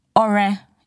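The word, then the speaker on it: Ọrẹ: And there's a dot under the O and a dot under the E to show that it is Ore and not Ore. And there's an accent on the U and the A to show that it goes Ore Oluwa, goes up and down.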